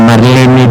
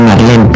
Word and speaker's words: Marlene: como Marlene Dietrich, por ejemplo, que todo el mundo dice Marlene, pero es Marlene.